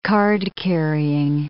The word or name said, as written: card-carrying